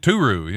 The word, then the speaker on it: Turu: It Turu, yeah. – T-U-R-U.